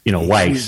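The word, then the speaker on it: Wyss: The guy from Switzerland, Weiss, I want to say, maybe it's Weiss. – That's W-Y-S-S, right? Is that how he's... – Right, yeah. Anyway, he said a lot of The Chicago pronunciation is, Weiss. – He's Weiss.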